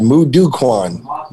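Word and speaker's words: Moo Duk Kwan: In addition, he's a black belt in what I will probably mispronounce, Mu Do Kwan, Taekwondo, and Kempo. How bad was that? – That wasn't bad. Mu Do Kwan.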